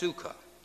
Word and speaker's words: sukkah: So the Hebrew term translated tabernacle is C-U-K-K-A-H, is pronounced sukkah, meaning a hut or a booth.